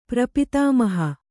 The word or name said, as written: ಪ್ರಪಿತಾಮಹಿ